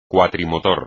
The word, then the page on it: cuatrimotor: espagnol